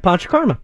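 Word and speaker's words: Panchakarma: What is a, how do you say it? Pancha Karma? – That is correct, Pancha Karma.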